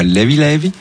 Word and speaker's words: Levé Levé: Alors volume 2 donc de Levé Levé, qui se prononce là-bas Levi Levi, ça veut dire léger ou tranquillou un peu, c'est en gros c'est il y a pas de stress.